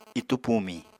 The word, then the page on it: itú pumiñ: apatani